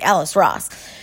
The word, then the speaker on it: Ellis Ross: Tracee Ellis Ross, is that, I feel like I'm saying her name wrong. It is, it's definitely Ellis Ross.